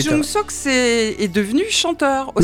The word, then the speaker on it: Cho Jung-sok: Alors je vous propose qu'on écoute un grand chanteur, surtout un grand acteur, il s'appelle Cho Jung-sok. – Oui. – J'espère le prononcer comme il faut. – Ça va. – Bon, ça va.